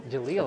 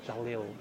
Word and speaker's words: Jaleel: It's the English pronunciation. – How do you pronounce it in your language? – I'm born here, so it's Jaleel. – Jaleel. – Okay. Yeah, but it's an Arabic name. It's like one of the 100 names of Allah. So it's the correct pronunciation is still Jaleel. – Okay, cool. – Or Jaleel, but it depends on where you are.